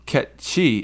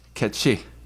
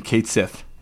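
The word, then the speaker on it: cait-sìth: And apparently, Ket She is a Scottish Gaelic, like mythical creature. And that's what it was, Ket She. And if you look up, the pronunciation, that's the pronunciation. If you look it up on, various websites, including, the Japanese pronunciation is, you know, I don't know, something similar, because that's how the Japanese do their shit. For years, it's always been Kate Sith. It's Ket She.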